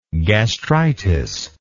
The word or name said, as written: gastritic